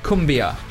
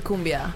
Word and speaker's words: cumbia: Okay, so what I wanted to kind of start the official inquiries about the music about was tell me about, and I'm almost certain that I'm going to pronounce this wrong, but tell me a little bit about cumbia. – Well, Uruguayan. – We grew up with cumbia, actually. – Yeah. – So, yeah, it's kind of like... – First of all, you pronounce it correctly.